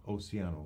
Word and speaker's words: Oceano: Is that how you pronounce it? Oceano or Oceano? – Oceano. – Oceano, I didn't know.